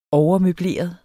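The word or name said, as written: overmøbleret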